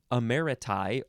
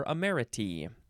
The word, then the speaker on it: emeriti: The plural is you replace the U-S with a T-I and you pronounce it emeriti or emeriti.